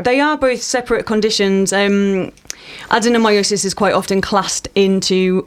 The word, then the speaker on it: adenomyosis: Why adenomyosis? Have I said that correctly? – Adenomyosis. You can just call it adenome.